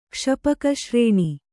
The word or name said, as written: ಕ್ಷಪಕಶ್ರೇಣಿ